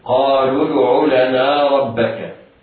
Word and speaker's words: قالوا ادع: ولا ننطق في حال الوصل بالواو ولا بالالف التي بعدها ولا بهمزه الوصل، قالوا ادع لنا ربك، لنا حروفها كلها مرققة، ربك الراء مفتوحة وقعت أول الكلمة وهي مفخمة، وباقي حروف الكلمة مرققة الباء والكاف.